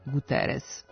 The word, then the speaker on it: Guterres: Tu S uvek ostaje S, pa Guterres u Portugaliji može da bude Guterres, ali kod nas on je Guterres.